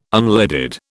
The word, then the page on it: unleaded: British English